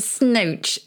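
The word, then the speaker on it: snoach: And to snooch is to speak through the nose.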